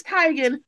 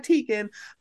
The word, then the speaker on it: Teigen: It's Teigen, not Teigen.